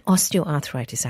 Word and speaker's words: osteoarthritis: But do you want to just kick off the show with, explaining to us what osteoarthritis actually means? – Well, you said it right.